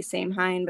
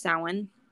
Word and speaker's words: Samhain: A lot of people say Samhain, but I think that's wrong. I think it's pronounced Samhain.